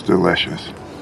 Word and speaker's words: delicious: It's delicious. – Es delicioso, or sabe delicioso. También tenemos la contracción con it's, it is. Y en la palabra delicious, vamos a subir la entonación en la segunda sílaba.